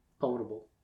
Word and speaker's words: potable: Potable water. – Potable. – Is that how it's pronounced? – Yep.